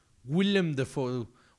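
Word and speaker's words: Willem Dafoe: William Defoe.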